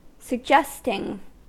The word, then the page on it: suggesting: American English